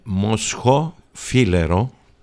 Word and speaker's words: Moscofilero: Moscho Filero, it's Mantinia, Agio Rigitico, Nemea, Assyrtico, Santorini.